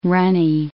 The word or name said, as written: runny